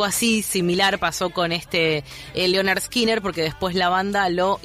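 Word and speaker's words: Lynyrd Skynyrd: Bueno, y si te pones a leer un poco la historia del nombre, lo que te cuenta es cómo se pronuncia, que sería Leonard Skinner, en este chiste que les estábamos contando de reemplazar todas las vocales por la Y.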